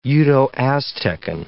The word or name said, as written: Uto-Aztecan